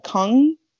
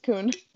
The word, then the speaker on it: Kughn: I think of Kung. I just butchered that last name. I have K-U- no idea. – I do think it's Kughn.